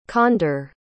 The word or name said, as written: conder